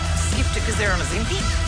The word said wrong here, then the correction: Ozempic: How do you say it? – Ozempic.